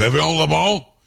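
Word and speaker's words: Évian-les-Bains: Evian-le-Bain. CJ, am I saying that right? Evian-le-Bain? – I think so, yeah.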